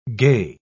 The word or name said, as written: gey